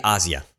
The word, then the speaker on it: Asia: It's actually Asia, yeah.